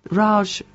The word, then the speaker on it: Raj: You make it sound like it's someone moaning. Raj, the Raj, isn't it? Sort of.